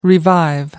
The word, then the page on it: revive: American English